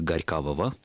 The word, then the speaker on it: Горькавого: Дело в том, что «ого» в русском языке века так с 14-го уже не читается как «ого», и мы как-то говорили о том, что там длительный процесс изменения этого согласного между двумя гласными, и, в общем-то, литературная норма как раз подразумевает пограничника Горькавого, так как и говорят в троллейбусе, в автобусе.